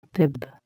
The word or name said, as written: bib